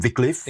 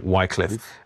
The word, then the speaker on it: Wycliffe: pronounce him Wycliffe. – Exactly. Yeah. And we call him Wycliffe.